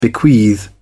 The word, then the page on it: bequeath: British English